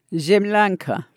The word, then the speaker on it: Zemlyanka: The camp is made up of, and I'll have you pronounce this word for me, Zemlyankas, is that right? – Zemlyanka. – Zemlyanka, which are log bunkers.